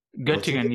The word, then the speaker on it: Göttingen: We played the basement of a church in Göttingen. Is that how you pronounce it? – Göttingen, yeah.